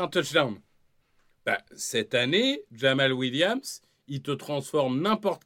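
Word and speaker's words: Jamaal: J'ai peur d'écorcher son prénom, mais c'est Jamal. Hein, Jamal, exactement, Jamal Williams.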